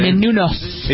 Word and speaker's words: Menounos: Extra host Maria Menounos. Is that how you pronounce it? – Menounos.